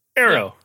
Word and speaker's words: Aero: Am I saying A-E-R-O? That's how you pronounce that. – Yeah, Arrow.